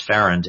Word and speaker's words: Farrand: One of the early, not so early, but one of the most prominent historians of the convention, Max, I think his last name is pronounced Farrand, F-A-R-A-N-D, of Yale, he described Morris as the most brilliant member of the Pennsylvania delegation.